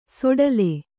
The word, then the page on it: ಸೊಡಲೆ: soḍale